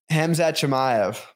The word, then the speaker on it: Hamzat Chimaev: Hamzat Chimaev, I always butcher it. – Hamzat Chimaev.